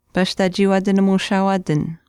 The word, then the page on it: paashtaachiiwaatinimushaawaatin: Chisasibi